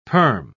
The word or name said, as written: perm